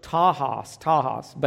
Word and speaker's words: tachos: It's the Greek word tahos, but it's spelled T-A-C-H-O-S. The word for soon is spelled T-A-C-H-O-S.